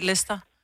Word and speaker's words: Leicester: Altså, der er jo bare Leicester. Nogle ting, du ikke udtaler. Det staves jo også sådan noget Leicester, men bliver bare udtalt Leicester.